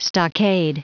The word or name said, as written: stockade